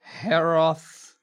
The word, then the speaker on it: Hroth: Gade Hroth Calmseer. – Gade Hroth. – Gade, H-R-O-T-H.